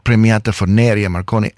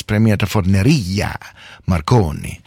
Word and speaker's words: Premiata Forneria Marconi: It is not Premiata Forneria Marconi, as I've been saying. It's Premiata Forneria Marconi.